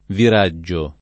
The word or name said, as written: viraggio